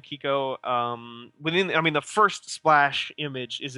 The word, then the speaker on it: Quico: And the game reflects that by putting you in the in control of a kid named Kiko, which is spelled Q-U-I-C-O, for the record.